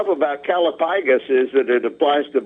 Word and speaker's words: callipygous: Calipygus. – Calipygus, C-A-L-L-I-P-Y-G-O-U-S, and according to the wordsmith, it is pronounced calipygus.